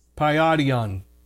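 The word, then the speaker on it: paidion: It's the Greek word paidon. And let me spell it for you. It's P-A-I-D-I-O-N. Paidon.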